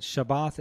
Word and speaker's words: Shabbat: And that's the word Shabbat is the Hebrew way of saying Sabbath.